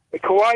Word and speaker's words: Kauai: Kauai is the island.